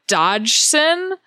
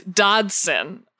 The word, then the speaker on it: Dodgson: And I did not understand that that was Dodson just because of pronunciation. I think everyone seemed to be really confused about the pronunciation of his name, because in this version it's more Dodgson. – Yeah. – And I think everyone who's ever seen Jurassic Park would say it's Dodson.